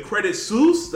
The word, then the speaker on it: Credit Suisse: And what is it, Credit Suisse? Did I say that right? – Yes.